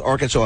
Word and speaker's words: Arkansas: It's the correct pronunciation too is North Arkansas Electric, right? – Cooperative.